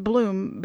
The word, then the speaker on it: Blum: It's pronounced Bloom, but it's spelled B-L-U-M.